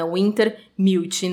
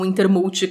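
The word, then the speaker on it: Wintermute: E aqui, detalhe que o, no livro faz questão de mostrar que eles pronunciam, né? Eles falam essa, a, a palavra de forma separada, né? Winter Mute, não Winter Mult como tudo junto, né?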